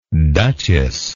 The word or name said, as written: duchesslike